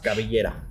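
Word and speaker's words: Cabellera: So you're able to use that mask, the Mascara Contra Mascara, or, you know, Mascara Contra Caballero. Is that how you say it for hair versus yes, mask? – Caballera.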